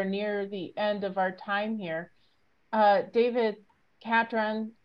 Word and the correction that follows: Catron: I just said it wrong. – Catron. – So I finally got my Catron. Catron. Right? – Catron. Like patron of the arts.